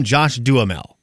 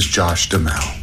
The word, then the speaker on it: Duhamel: Hi, my name is Josh Duhamel. Duhamel, not Duhamel, not Duhamel, and definitely not Duhamel.